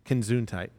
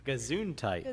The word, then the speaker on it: Gesundheit: you still didn't say it right. – How do you say it? Gazuntite. – Gazuntite. – That's what I just said. Consuntite. hey, I'm sorry. Let me try it again. Consuntite. No! There's no C in there.